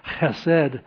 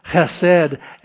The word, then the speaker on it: chesed: The word is H-E-S-E-D, and if you do it with a pronunciation, it's can you get that sound going? Chesed. Chesed.